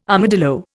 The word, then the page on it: armadillo: Indian